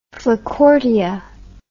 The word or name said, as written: flacourtia